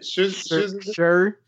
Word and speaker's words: Scherzinger: Nicole Scherzinger? Scherzinger? The chick from the Pussycat Dolls. I don't know how to pronounce the last name. I like wings. Scherzinger. Say it. – Scherzinger. – Okay. Thank you.